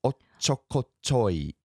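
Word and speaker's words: ochokochoi (おっちょこちょい): So anyway, how do you pronounce that? It's ochokochoi. – Right. Ochokochoi. – Ochokochoi. Yeah, it's a... – There's a little hiccup with the little tsu at the beginning.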